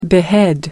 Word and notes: behead: American English

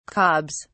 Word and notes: cobs: British English

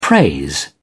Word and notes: praise: British English

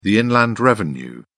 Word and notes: inland revenue: British English

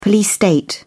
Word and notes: police state: British English

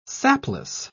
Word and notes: sapless: British English

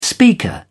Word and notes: speaker: British English

